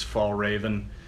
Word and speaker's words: Fjällräven: if you pronounce it, I guess in English, it's Fjällräven. In the United States.